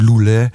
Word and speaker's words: Loulé: Le, ja, Loulé, Loulé, Loulé, spreek je uit Loulé.